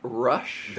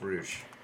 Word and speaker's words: Rush: It is pronounced Rush in America. – How dare you. – It is definitely you racist person. It is pronounced Roosh in Espanol.